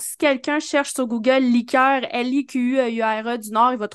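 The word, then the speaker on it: likeur: assez semblable, c'est liqueur, liqueur,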